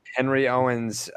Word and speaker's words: Henry Owens: Henry Owens, tall, lanky lefty.